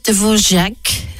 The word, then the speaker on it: Dvořák: Oui, en fait, Dvorak est un tchèque, et sous le nom qui est écrit avec un R, avec un circonflexe à l'envers, qui fait que le R est prononcé comme un J.